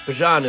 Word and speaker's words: Bijan: Bijan is the pronunciation. Bijan just sounds cooler.